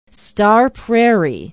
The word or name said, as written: star prairie